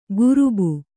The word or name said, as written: ಕುರುಬು